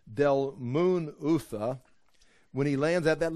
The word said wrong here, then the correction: Dalmanutha: How would you say it? Dalmunutha? Dalmanutha. Is that what you would say? – That's what I would say. – Okay. So you got the syllable Dalmanutha. Okay, Dalmanutha.